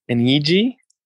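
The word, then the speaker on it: Eegee's: An EG? – Yeah, it's spelled EEGEE apostrophe S.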